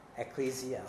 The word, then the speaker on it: Ecclesia: 那其实教会这个字 Ecclesia 上去 Ecclesia， OK，準確發音，多謝。